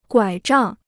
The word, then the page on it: 拐杖: guǎi zhàng